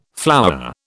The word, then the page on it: flowerer: British English